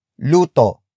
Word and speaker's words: luto: Magluto is spelled as M-A-G-L-U-T-O. It comes from the root word luto.